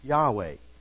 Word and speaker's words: YHWH: The name Lord is the letters YHWH. It is pronounced Yahweh.